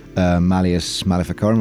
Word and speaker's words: Malleus Maleficarum: As you say, it wasn't necessarily based on a specific source, and he'd spent 2 years studying the Malleus Maleficarum, am I saying that right? – Yeah.